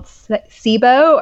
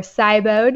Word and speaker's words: SIBO: And let's see, other things that can cause leaky gut, gut dysbiosis, specifically something called SIBO or SIBO, depending on who you speak to as far as that's pronounced.